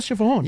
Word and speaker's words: Siobhan: S-I-O-B-H-A-N.